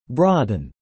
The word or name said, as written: broaden